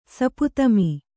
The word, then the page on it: ಸಪುತಮಿ: saputami